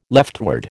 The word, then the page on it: leftward: American English